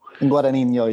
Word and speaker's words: Guadagnino: I think it's Guadagnino. – Guadagnino. Yeah, Guadagnino. Exactly. It's like, which consonants am I supposed to leave out? – Yeah.